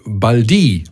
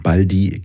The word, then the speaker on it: Baldi: Ähm, dass du nämlich gar nicht Baldi heißt, sondern Baldi, also diese Betonung auf dem I hinten raus und wir das eigentlich seit 25 Jahren falsch sagen, stimmt das? Ist Quatsch. Ist Quatsch.